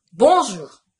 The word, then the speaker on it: Bonjour: Bonjour! Bonjour! こう、始めましたね。皆さんご存知ですか？ Bonjour。日本語ではカタカナで Bonjour なんていう風に言われたりしますけれども、基本挨拶の一つ。 Bonjour。こんにちは。こちらはですね、 Bonjour といったるではなく、 Bonjour。 Bonjour。という風に発音します。